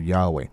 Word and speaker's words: Yahweh: It's probably Yahweh, and so the word Yahweh, and is probably a better pronounce uh, pronunciation for the for that name.